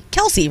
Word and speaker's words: Kelce: Whoever wrote this game, which I know was you, Elvis, how do you pronounce Kelsey? Kelsey, right? – Rhymes with Halsey and Ballsy.